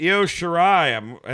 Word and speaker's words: Io Shirai: And Candice LeRae versus Io Shirai.